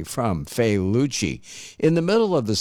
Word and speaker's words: Failaci: They're called Failucci. Let me spell that for you. F-A-I-L-A-C-I.